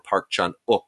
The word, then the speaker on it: Park Chan-wook: The one of these arrows that I'm really, really fond of is the Park Chan-wook, or Park Chan-wook, I'm told is the correct pronunciation.